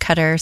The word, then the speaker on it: Qatar: He pronounces it Qatar, so I'll say it that way.